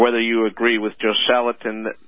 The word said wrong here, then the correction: Joel Salatin: I might have mispronounced that. – Joel Salatin.